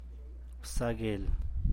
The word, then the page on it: bzaa guel: San Mateo Macuilxochitl Zapotec